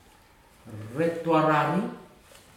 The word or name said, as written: retu arari